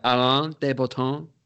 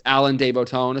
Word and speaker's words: Alain de Botton: And it's by this guy, Alain de Botton. That's how you say it in French. But it's Alain de Botton, if you want to say it all American.